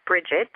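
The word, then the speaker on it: Brigid: Some of you may know her as Bridget, um, but the ancient Gaelic pronunciation is Breed.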